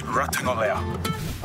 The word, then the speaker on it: Ratugolea: Geelong Ruckman, Asava Radagalia. Asava Radagalia. Lock it in. My last name is pronounced Ratugolea.